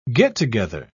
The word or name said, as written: get-together